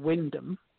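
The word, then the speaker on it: Wymondham: The place name is actually spelt Wymundham, which is W-Y. Mondham, and that is a place in Norfolk called Wyndham, even though it's spelt Wymundham.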